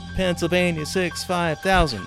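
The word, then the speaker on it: Pennsylvania 6-5000: Is that how you say it? 6500? – Wait for it. Pennsylvania 65,000.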